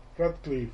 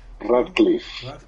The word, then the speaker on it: Radcliffe: Y bueno, pues ya saben que Daniel Radcliffe, ¿cómo se, pronuncia? Radcliffe. – Radcliffe. – Así es.